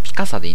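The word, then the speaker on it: Picasa: ピカサでいいんだね。ピカサか。なんか中途半端だな、ピカサ。ピカサ。ピカサ。うん。微妙じゃね？どうしてもこう、ピカソって言いたくなるんだ、きっとな。なあ。だろうな。まあそんなことでね、ピカサを使っているわけですよ。いいね、これ。早いしね。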